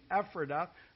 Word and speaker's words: Ephrata: Micah 5, 2 is quoted here in verse 6, which tells us it's Bethlehem, I'll give you the Pennsylvania pronunciation, Ephrata, it's a town in Lancaster County, Pennsylvania.